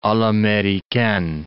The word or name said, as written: a l'americaine